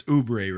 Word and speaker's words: Oubre: It's Oubre, right? Am I saying that right? – Yeah.